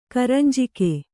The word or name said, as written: ಕರಂಜಿಗೆ